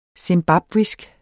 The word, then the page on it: zimbabwisk: simˈbɑbvisg; sem-; -ˈbɑbwisg